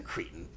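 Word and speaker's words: Cretton: Jordan's lead actor in this one is being directed by a guy, the guy who did Short Term 12, Destin Daniel Creighton or Cretin. How do you say that? Okay. – Cretin. Sorry.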